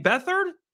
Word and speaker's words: Beathard: CJ Beathard, I think that's how you say his name.